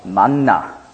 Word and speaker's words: Manna: Dann in der griechischen Aussprache später hat man dieses Wort ausgesprochen als Manna. Darum die neutestamentliche Form Manna ist eigentlich die griechische Aussprache von dem hebräischen Wort Man.